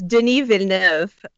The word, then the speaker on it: Denis Villeneuve: Are you a fan of, I guess it's Denis or Denis. How do you say that guy's name? Denis, I guess. But do you like his work? Have you seen these movies? – It's Denis Villeneuve.